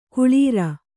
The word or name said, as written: ಕುಳಿರ್